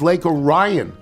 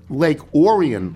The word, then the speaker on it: Orion: Like a dope, I asked her, how do you pronounce Lake Orion? She responded resoundingly that it is Lake Orion, like the bread, not Lake Orion, like the constellation or Orion. It's Orion.